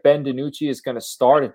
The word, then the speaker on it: DiNucci: And then DiNucci, obviously, I don't even know if I'm pronouncing that name right. – It's DiNucci.